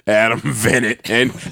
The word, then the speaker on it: Venit: Yeah, I'm not putting any nigga named Venit is not coming near me. Rhymes with Innit. Nope. Adam Venit.